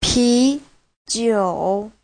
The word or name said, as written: pi2jiu3